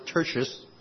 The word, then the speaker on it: Tertius: In Romans chapter 16, verse 22, there's a verse and it says this, I, Tertius, T-E-R-T-I-U-S, some pronounce it Tertius, Tertius, who write this letter, greet you in the Lord.